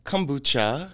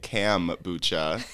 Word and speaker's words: kombucha: I'm sipping some organic kombucha. – Yeah. – That the guy downstairs laughed at me for pronouncing it cam-bucha.